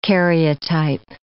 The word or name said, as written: karyotype